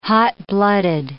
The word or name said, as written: hot-blooded